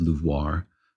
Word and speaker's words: LUVOIR: You know, I actually spoke with one of the scientists involved, and she pronounces it LUVOIR. I don't think she pronounces it with the LUVOIR, exaggerated Pepe Le Pew accent.